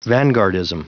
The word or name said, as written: vanguardism